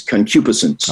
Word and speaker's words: concupiscence: and it is concupiscence.